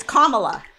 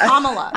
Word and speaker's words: Kamala: It's Kamala. Kamala Harris. – My friend calls Kamala Kamala. – No, it's Kamala. – I know, but people say Kamala.